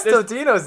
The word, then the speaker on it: Totino's: Like, I kept saying Tostino's. Maybe has everyone been saying their company name wrong? – It's a little pizza rolls? – Yeah, it's a little ones. – No, that's Tostino's. – No, it's Totino's, isn't it? – It says Totino's there. I'm very confused.